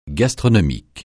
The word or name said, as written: gastronomique